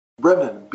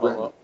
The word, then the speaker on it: Brennan: It's spelled M-E-J-I-A. Brennan. B-R-E-N-N-A-N. Not Brendan.